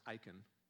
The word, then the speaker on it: Achan: All because of one guy, primarily Achan, literally it's pronounced Achan.